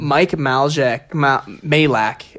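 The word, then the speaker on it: Majlak: Mike Malak is how you say it.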